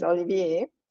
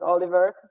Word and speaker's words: Olivier: So for my husband, his name is Olivier, but it's spelled Olivier. So, and people in America like completely miss the I, so it's just Oliver for everybody in the US, but he's been correcting them.